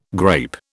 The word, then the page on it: grape: British English